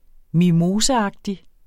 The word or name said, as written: mimoseagtig